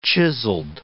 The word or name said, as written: chiselled